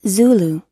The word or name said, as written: zulu